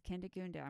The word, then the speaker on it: Kindegunda: It's Kindegunda. So. oh, it's what? it's Kindegunda.